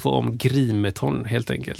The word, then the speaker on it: Grimeton: Radiostationen i Grimeton, uttalas Grimeton, är ett industriminne som sedan 2004 står på UNESCOs lista över världsarv.